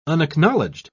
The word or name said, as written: unacknowledged